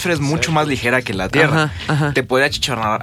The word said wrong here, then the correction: achicharrar: a, achichonar. – No, achicharrar. – achicharrar.